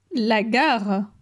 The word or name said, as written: La gare